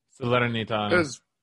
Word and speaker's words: Salernitana: We had Mirko Antonucci, Roma's young winger, went to Salernitana. How was the pronunciation on that? – Salernitana.